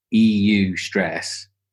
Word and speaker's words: eustress: So you have, so when you say stress, this is distress, because this is this word eustress. Is that, I don't know if that's how you pronounce it. It's eustress. So distress is the bad one, you know, not eustress, which sounds like a Brexit thing.